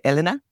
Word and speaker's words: Elena: Some people say Elena, I say Elena, which is the Spanish pronunciation.